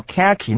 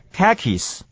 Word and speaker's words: khaki: 卡其的英文念法念作 khaki，那个 H 不发音的是念 K-A-K-I-S khakis